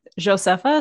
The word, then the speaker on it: Josepha: Yeah, it's actually it's Josepha, so with a soft J on the front. Josepha Hayden Chamfosi, and not the F in the PH in the last name.